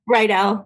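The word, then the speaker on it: Riedell: I've never known that's how you pronounced it. – Yeah, Raydell. Yeah.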